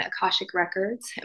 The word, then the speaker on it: Akashic Records: Akashic Records.